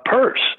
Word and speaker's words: Peirce: Peirce. It, it's spelled Pierce, but it's pronounced Peirce.